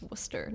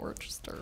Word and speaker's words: Worcester: It's Worcester, not Worcester. Wow, English.